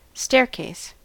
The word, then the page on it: staircase: American English